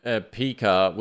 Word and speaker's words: Pika: So the other one I wanted to quickly call out is, I think you pronounce it Pika because it's like Pikachu, but Pika, which was a wait list and now is readily available.